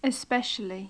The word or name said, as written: especially